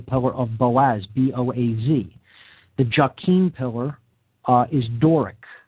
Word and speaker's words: Jachin: The pillar marked with the letter S there on the left is called the pillar of Jachin, sometimes pronounced Jachin. That's J-A-C-H-I-N.